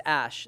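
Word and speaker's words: Æ: That's, oh, it's Ash. The second symbol is pronounced Ash.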